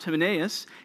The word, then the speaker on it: Hymenaeus: And the first guy, Hymenaeus, that's how we'll say it's pronounced, Hymenaeus, he's also called out in 1 Timothy.